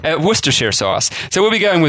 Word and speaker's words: Worcestershire: So in this episode, Worcestershire sauce, and yes, that's how it's pronounced, approximately. I grew up about 2 hours from Worcester, so although locals in the city may disagree slightly with my accent, I'm not too far off.